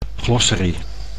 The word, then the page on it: glossary: Dutch